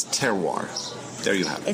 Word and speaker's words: terroir: You know, I had to do that because I have a hard time saying terroir. Did I say it right? – You said it right.